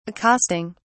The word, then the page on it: accosting: British English